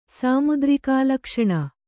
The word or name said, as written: ಸಾಮುದ್ರಿಕಾಲಕ್ಷಣ